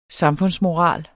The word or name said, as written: samfundsmoral